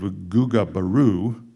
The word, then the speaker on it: Ugbaru: There's a guy by the name of Ugabru. You pronounce U-G-B-A-R-U.